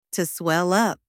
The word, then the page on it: to swell up: anglais